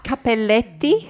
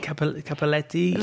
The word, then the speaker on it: Cappelletti: Cup, Cappelletti. – Cappelletti.